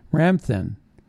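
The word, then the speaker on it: Ramthun: Now, Ramthin, if you want to look him up, is spelled R-A-M as in Mary, T-H-U-N. Ramthin.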